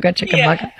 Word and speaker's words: Chickamauga: and also in Chickamauga, Georgia. Did I say that right? – Chickamauga. – Chickamauga. – Yeah, it's a Cherokee word.